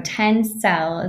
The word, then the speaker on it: Tencel: So Tencel is a correct pronunciation, made of wood, actually, a fiber.